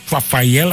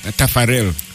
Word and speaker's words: Taffarel: I think the Brazilian, was it Rafael? How do they pronounce that? The goalkeeper. – Tafarel.